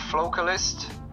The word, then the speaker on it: Focalist: Yeah, I'm going to go with Focalist. That's how I pronounce it. – Ah, Focalist, yeah. I think I missed the L in that one, yeah.